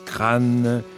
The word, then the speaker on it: Craon: La chanson de Craon, qu'il faut prononcer crâne, comme les crânes qui remontent encore, toujours et toujours à la surface.